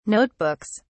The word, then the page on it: notebooks: British English